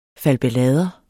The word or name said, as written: falbelader